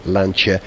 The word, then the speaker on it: Lancia: Saying it right, not a Lancia, Lancia.